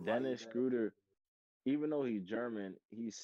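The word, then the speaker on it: Schröder: How do you say Schroeder? – Schroeder.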